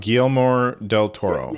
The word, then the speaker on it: Guillermo del Toro: It's directed by Odero Toro. Is that how you pronounce his name? – Guillermo del Toro. – Yeah. That's it, Guillermo del Toro.